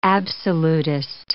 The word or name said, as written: absolutist